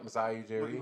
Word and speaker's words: Masai Ujiri: Masai? Mm-hmm. – Yep, Masai Ujiri.